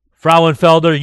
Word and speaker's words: Frauenfelder: Frauenfelder.